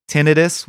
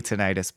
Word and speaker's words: tinnitus: Cause she has like tinnitus, which is what she says. And I can't believe that's the right pronunciation. It's gotta be tinnitus, but she says tinnitus.